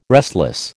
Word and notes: restless: American English

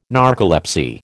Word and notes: narcolepsy: American English

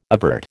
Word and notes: avert: American English

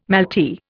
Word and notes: malty: Indian

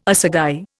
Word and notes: assagai: Indian

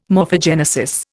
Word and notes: morphogenesis: Indian

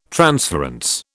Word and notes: transference: British English

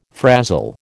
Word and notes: frazzle: American English

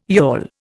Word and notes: yawl: Indian

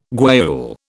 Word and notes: guayule: British English

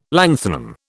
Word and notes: lanthanum: British English

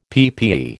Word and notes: PPE: American English

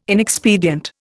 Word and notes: inexpedient: Indian

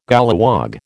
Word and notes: golliwog: American English

